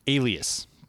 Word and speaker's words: alias: The Elias, words are freaking hard right now. – Yep. – That's not how you are supposed to pronounce it, and I don't know why I can't get it right. Alias. Dang it, that's it.